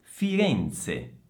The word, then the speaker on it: Firenze: Firenze, Firenze Attenzione al suono della Z, anche qui è la stessa Z di pizza, quindi Z, Z, Firenze, Firenze. Atterraggio, atterraggio, attenzione in questa parola c'è un festival di doppie, quindi atterraggio, atterraggio.